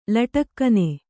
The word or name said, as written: ಲಟಕ್ಕನೆ